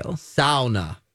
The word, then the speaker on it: sauna: Both sides of my family are from Finland, and I can confirm that you are pronouncing it correctly, and I thank you. – Sauna.